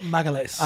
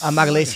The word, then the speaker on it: Magalhães: We spent time in Vegas, and he was working with Vinny Magalhães. I hope I'm saying the name right. – Magalhães. – Magalhães.